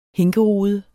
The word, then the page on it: hinkerude: dansk